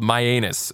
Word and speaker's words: Mianus: This whole thing is basically just Johnny Knoxville going to a town in Connecticut, literally called Myanus. It's spelled M-I-A-N-U-S. Myanus, pronounced just like that.